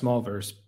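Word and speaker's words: Smolverse: And is it pronounced, it's pronounced Smallverse, but it's spelled S-M-O-L.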